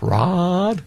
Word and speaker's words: Raad: R-A-A-D. What's the pronunciation of that? Raad? – Yeah, Raad.